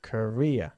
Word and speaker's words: Korea: Corea, Korea. – Korea. – In English, Corea, Corea del Sur, Corea del Norte, se dice Korea. – Okay. – Se pronuncia igual que Korea, como carrera profesional.